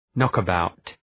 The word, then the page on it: knockabout: ,nɒkə’baʋt